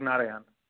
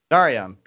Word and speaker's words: Narayan: Uh, you got it right, Karthik Narayan. Narayan, perfect, perfect.